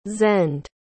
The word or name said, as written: zend